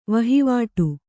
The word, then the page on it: ವಹಿವಾಟ್: vahivāṭ